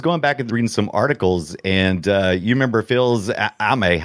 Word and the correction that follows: Fils-Aimé: How do you say it? – Fils-Aimé. – You know, Reggie Fils-Aimé. – Phil's, there you go.